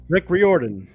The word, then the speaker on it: Riordan: Rick Riordan.